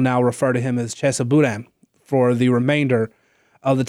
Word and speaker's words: Boudin: Take Chesa Boudin, I think is how he pronounces it.